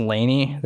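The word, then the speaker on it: Lany: I think it's pronounced Laney. They're more of a, it's L-A-N-Y.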